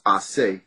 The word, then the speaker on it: Ace: my full name is Asi Mandisi, which is A-C-E is the first three letters, which is really supposed to be pronounced Ase.